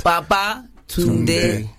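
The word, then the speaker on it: Babatunde: Tunde. – Tunde. – So say it like you're saying come back or returned. – Baba Tunde.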